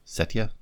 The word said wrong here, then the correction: Satya: I don't know how to pronounce it. Satya. Anyway.